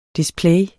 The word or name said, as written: Displays